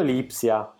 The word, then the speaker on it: Lipsia: Ma perché fai tutto queste pronunce arzigogolate? Red Bull Lipsia. – Sì, è Lipsia, hai ragione, Lipsia, perdonami.